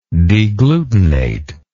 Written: deglutination

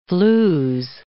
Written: flews

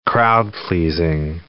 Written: crowd-pleasing

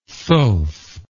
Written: thoth

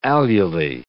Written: alulae

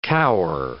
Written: cower

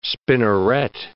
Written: spinneret

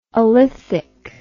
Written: alethic

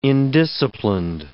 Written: indisciplined

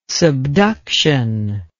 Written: subduction

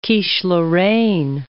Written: quiche lorraine

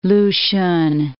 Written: Lushun

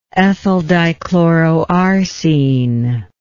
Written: ethyldichloroarsine

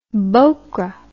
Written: bhokra

